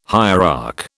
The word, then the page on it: hierarch: British English